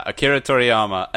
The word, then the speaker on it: Akira Toriyama: And then the other gentleman you may have heard of, I probably say it wrong again, is Akira Toriyama? – Yeah, Akira Toriyama, another legend.